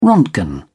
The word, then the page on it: roentgen: British English